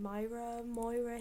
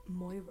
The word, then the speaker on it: Myra: It's spelled Myra, but everyone I've spoken to says like Moira. – Moira.